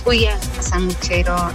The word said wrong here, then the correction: El Sanguchetto: El Sanguchetto. – El 31 y 40. – Sí, ¿y? – Muy rico. – No, por ahí no lo dije bien y no lo entendió. El Sanguchetto.